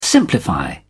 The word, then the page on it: simplify: British English